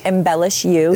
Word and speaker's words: Embellish U: Embellish You. Am I saying that properly? – Embellish You. – Embellish You. – Just the letter U.